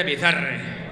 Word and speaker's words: Bizarre: Paso de pronunciarlo en inglés porque era... Toma por culo. Estamos en España y se dice Bizarre.